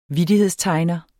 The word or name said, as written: vittighedstegner